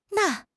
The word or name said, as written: na4